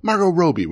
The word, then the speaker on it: Margot Robbie: Margot Robbie would be easier to say. Long O's. Just do Margot Robbie. Margot Robbie.